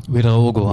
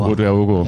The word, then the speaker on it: Ouedraogo: Und dann hast du natürlich Ouedraogo, so heißt er, ne? – Ouedraogo. – Ouedraogo, ja. Den kann man auch nur ohne Bier aussprechen.